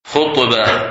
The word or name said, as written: Khutbah